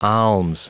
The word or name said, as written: alms